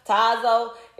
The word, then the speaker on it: Tazo: I think that's how you say it. Tazio. Tazo. It's that T-A-Z-O.